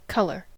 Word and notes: color: American English

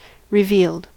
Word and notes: revealed: American English

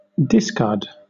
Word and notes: discard: British English; noun

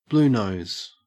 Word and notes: bluenose: Australian English

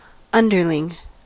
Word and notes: underling: American English

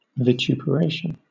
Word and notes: vituperation: British English

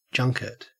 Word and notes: junket: Australian English

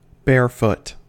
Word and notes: barefoot: American English

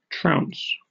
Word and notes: trounce: British English

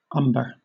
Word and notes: umber: British English

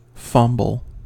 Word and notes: fumble: American English

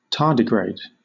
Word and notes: tardigrade: British English

